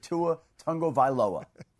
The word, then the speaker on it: Tagovailoa: Tua himself says you pronounce it Tua Tagovailoa, right? So there it is. I think I'm getting it pretty close.